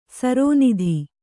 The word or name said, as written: ಸರೋನಿಧಿ